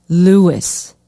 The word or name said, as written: Lewis